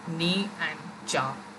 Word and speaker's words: Nietzsche: Some call him Nietzsche, some call him Niche, some call him Nietzsche, but the actual way of pronouncing it is something like knee and cha. So it's like your knee and the Hindi word for chai, like for tea, chai, so cha, like that.